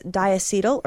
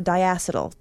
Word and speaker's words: diacetyl: One byproduct is diacetyl or diacetyl. I've heard it said both ways, so I'm going to pronounce it both ways to just kind of appease both camps.